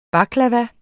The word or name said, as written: baklava